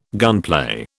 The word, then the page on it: gunplay: British English